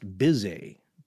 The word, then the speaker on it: Bhise: Bise, his last name is spelled B-H-I-S-E, is a fellow from India. I think the wiki entry will spell it B-I-S-L-E-Y as Bisley, but other entries will say it's pronounced Bise. Yes, there are jokes there. B-I-S-E-Y.